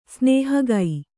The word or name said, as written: ಸ್ನೇಹಗೈ